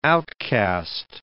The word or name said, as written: outcast